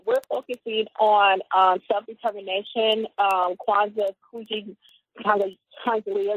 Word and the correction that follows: Kujichagulia: excuse me for Kuji, mispronouncing. Kujichagulia? Um, yes.